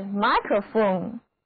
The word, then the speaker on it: microphone: 那如果把它放在 phone 的前面，得到的新单词就是 microphone，字母 I 读它的本音 I，而字母 O 读作短的 a。